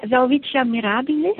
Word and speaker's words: Welwitschia mirabilis: it's the Welwitschia mirabilis, is that correct? – Yeah, Welwitschia mirabilis.